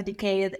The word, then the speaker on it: decade: No, so syllable stress on the first one. Decade. – I've spent in FinTech. I spent a decade in industry. – Decade. And I'm decade. Decade. Like a deck of cards.